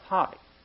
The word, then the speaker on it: hoti: It's just the Greek word is H-O-T-I, pronounced haughty.